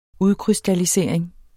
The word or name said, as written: udkrystallisering